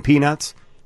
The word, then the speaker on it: peanuts: pronounce properly the T in peanuts?